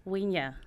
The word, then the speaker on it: Hwinwa: Winya. – Winya. – Yes. – Why is there an H? – It's silent. It's a silent urge.